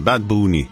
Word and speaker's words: Bad Bunny: stavas Bad Bunny, men eftersom Bad Bunny är puertorican och både pratar och sjunger på spanska så blir det liksom Bad Bunny istället. Fick vi lära oss, vår reporter Gosha pratade med några spanjorer nyss som sa att det är så man ska uttala det, Bad Bunny.